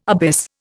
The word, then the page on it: abyss: Indian